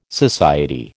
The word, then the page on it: society: American English